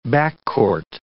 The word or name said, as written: backcourt